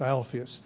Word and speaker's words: Alphaeus: And Alphaeus, I keep saying Phaeus, but Alphaeus is, I think, the correct pronunciation.